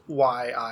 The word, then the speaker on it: _yi: So I think I remember seeing somewhere that it said pronounced YI, as in like the letters.